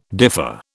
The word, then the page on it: differ: British English